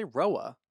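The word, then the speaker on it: RHOA: Say Roa. – Because the H. – Yeah, but it could be silent. – Roa. Roa. – If somebody's last name is R-H-O-D-E-S, how do you say that? – Rhodes. – Jesus. I kind of walked right into that one. Yeah, but yeah, just call it Roa.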